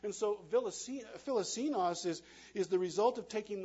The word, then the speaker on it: philoxenos: On your note page, and we'll put it up on the screen as well, the word hospitality comes from a Greek word pronounced philoxenos. You can give that a try, philoxenos.